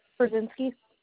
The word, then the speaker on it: Brzezinski: Brzezinski. – Ooh, spell that for me. – B-R-Z-E-Z. – Mm-hmm. – I-N-S-K-I.